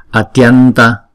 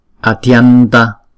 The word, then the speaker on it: atyanta: Atyanta, atyanta. Thấy không? Cũng giống như là atiyanta, nhưng mà chúng ta đọc nhanh thì là atyanta, atyanta. Karunika, sankara swaha.